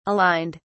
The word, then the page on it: aligned: British English